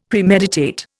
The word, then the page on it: Premeditate: Indian